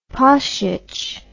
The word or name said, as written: pasic